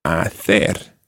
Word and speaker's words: hacer: We're going to take this time hacer. – Hacer. – Now, hacer is spelt H-A-C-E-R. And if you're in Latin America, you'd be saying hacer.